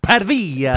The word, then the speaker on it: Pavia: It's pronounced Pavia.